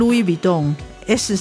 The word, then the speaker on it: Louis Vuitton: 如果在它的原产地法国，他们是念 Louis Vuitton，S 是不发音的。